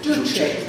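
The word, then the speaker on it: Juche: Then you get, I don't know how you pronounce Yush, Kim Il-sungism. What is it? – Duce. – Thank you, Duce.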